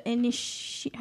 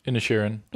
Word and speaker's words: Inisherin: The Banshees of Inisherin. How do you say that word? – Inisherin. – Inisherin.